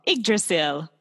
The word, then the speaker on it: Yggdrasil: Yggdrasil. – Yggdrasil.